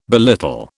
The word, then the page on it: belittle: British English